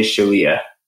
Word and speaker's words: Chelliah: So my mom and dad say Chelliah, and then my cousins say Chelliah, and then kind of the more American way is Chelliah.